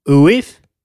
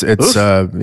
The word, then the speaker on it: oeuf: Madame Ouif. Is that how you say it? That's how it's written. O-E-U-F. – Ouf. Ouf. It's Ouf.